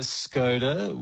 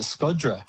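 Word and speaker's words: Shkodra: Yeah, and we went to Skoda, which they actually pronounce Scod. They pronounce it Skodra.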